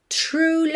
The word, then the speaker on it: true: True love. Aqui, ok? Atenção à pronúncia dessas palavras. True, tem biquinho, ó.